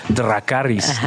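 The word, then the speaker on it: Dracarys: Porque, porque hay una diferencia, por ejemplo, la pronunciación de la R. digamos, en un alto valirio normativo tendrías que decir Dracaris, ¿no? con la R como, como muy este, marcada.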